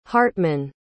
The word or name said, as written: hartman